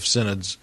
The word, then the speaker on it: synod: And by the way, for our listeners, this term synod is spelled S Y N O D, synod.